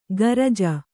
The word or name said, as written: ಗರಜ